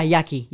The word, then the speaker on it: Yaqui: Sorry, did I say that right? – Yeah, Yaqui. Yeah. – Okay, right. – Yes. – Okay. Y-A-Q-U-I.